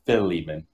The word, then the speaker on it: Philemon: Pronounce it Philemon, as in Philip, but Lehman Philemon.